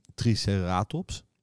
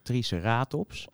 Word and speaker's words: Triceratops: Officieel is het Triceratops. maar dat komt een beetje, want het, het is een, een Latijns-Griekse naam. En daar valt de klemtoon op de ene laatste lettergreep. Hè, diplodocus.